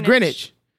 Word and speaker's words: Greenwich: So it's Greenwich. Why is a W silent?